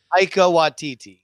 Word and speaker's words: Taika Waititi: And don't forget that Taiki Waititi, or however you say his name. – Taika Waititi.